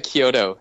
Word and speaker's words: Kyoto: Kyoto.